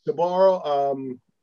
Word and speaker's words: Sbarro: Sbarro.